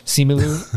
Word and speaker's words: Simu Liu: Is that how you pronounce the guy who plays Shang-Chi? – Yes. – Simu Liu.